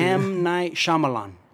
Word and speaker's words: Shyamalan: you pronounce his last name. I don't know how to say that shit. – M. Night Shyamalan. – There you go. That's like pretty good, actually.